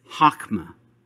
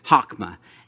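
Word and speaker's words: chokmah (חכמה): Wisdom in Hebrew is chokmah. Let's try saying that, chokmah. H-O-K-M-A, chokmah. Now say it with a little bit of saliva. Chokmah, chokmah. Yeah, now you got it. That's how you say it.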